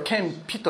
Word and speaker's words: 謙卑: 悪魔のすべての策略を知り尽くした方が捉えた最後の方法、それが徹底した謙遜、これを謙卑と言います。謙遜の謙という字ですね。卑しいという字を書いて謙卑と読みますけれども、徹底した謙卑、十字架の死であったのです。